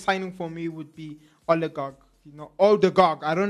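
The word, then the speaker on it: Ødegaard: I hope I say his name right. Oligarch. No, I'm saying his name so wrong. I'm butchering his name. I am so sorry. Odegaard.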